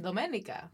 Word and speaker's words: Domenica: I know I'm always like, it's taken me a while to now say, I'm Domenica. – Yeah. – To pronounce it the way my parents intended for my name to be.